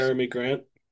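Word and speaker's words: Jerami Grant: Jeremy Grant. – That's how you say his name. It's spelled so Yeah.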